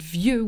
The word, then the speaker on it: vieux: And then you have this word, the word vieux, which means old, and that ends with an X at the end.